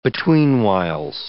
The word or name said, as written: betweenwhiles